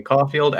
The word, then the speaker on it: Caulfield: Uh, you can find me on the internet at Call Me Caulfield. As you can see down below, it is spelled C-A-L-L-M-E-C-A-U-L-F-I-E-L-D.